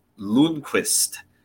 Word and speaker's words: Lundqvist: The actual pronunciation is Nils Lundqvist.